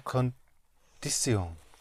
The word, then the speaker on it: condición: He aceptado la condición. – La condición. – La condición. – Yes. All the IONs have an accent on the O.